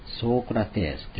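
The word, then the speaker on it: ソークラテース: プラトンというプラトーンというわけです。アリストテレスですね、アリストテレスですね、ソークラテスというのが本来の発音です。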